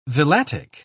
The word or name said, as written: villatic